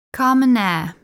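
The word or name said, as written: Carmenère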